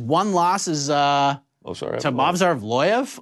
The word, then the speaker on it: Evloev: Evloev.